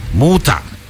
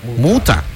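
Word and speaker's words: Mutter: Muta. ¡Qué bárbara! Así se pronuncia. Muta. Póngale un poquito más de así, picante a la T y ya estamos.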